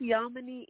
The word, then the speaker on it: Yamoni: I think is it Yami or Yami? – Yami. Yamani? Is that it? Y-A-M-O-N-I? – Yes. – I may be. How do you pronounce that? – It's Yamani Essentials.